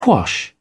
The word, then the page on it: quash: British English